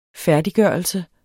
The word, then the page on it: færdiggørelse: [ -ˌgɶˀʌlsə ]